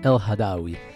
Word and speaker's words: El Hadawi: Zo, wil je heel even je eigen achternaam uitspreken? – El Hadawi.